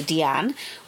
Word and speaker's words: Diane: Her mother, who pronounced her name in the French way, Diane, was a distant woman, rarely interacting with her children.